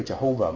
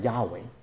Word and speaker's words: Yahweh: 有好多人認為呢個 Jehovah 唔係真正嘅 YHWH 嘅拼音嘅發音，真正嘅發音可能係 Yahweh，但係就係 1000年前就有人咁做，所以就出咗耶和華呢個字出嚟。